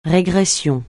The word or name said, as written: régression